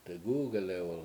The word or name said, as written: degUgelew1l